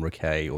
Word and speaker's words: Rakai: He says Jordan Rakai or Jordan Rakai himself, but he's not really offended by mispronunciations of his last name, apparently.